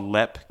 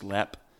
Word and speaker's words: Leap: It's called Lep Castle. It's spelled like Leap, but I'm pretty sure it's pronounced Lep.